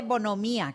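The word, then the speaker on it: bonhomía: Celia, pues, se dice bonomía, que lleva acento en la I.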